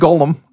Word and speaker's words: golem: It doesn't matter. The word is pronounced golem.